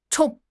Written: zhong4

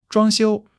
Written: zhuang1 xiu1

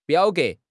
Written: biao3 ge2

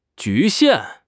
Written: 局限

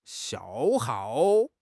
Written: 消耗

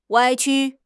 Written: 歪曲

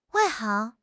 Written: 外行

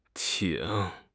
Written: ting3

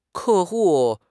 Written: 客户

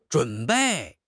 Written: zhun3 bei4

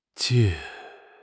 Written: jie4

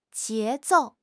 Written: 节奏

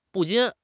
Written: 不禁

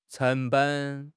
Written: 成本

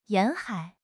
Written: 沿海

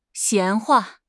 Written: 闲话

